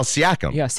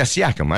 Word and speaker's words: Siakam: Siakam. Did I say that right? No, it's not sounding right. Pascal Siakam. – Yeah, Siakam.